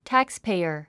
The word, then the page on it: taxpayer: tǽkspè(i)ə r; タックスペイヤァ